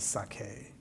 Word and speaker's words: saké: It's spelled S-A-K-E. I think it's pronounced Saké. – Well, that's how most people pronounce it. The real pronunciation is Saké.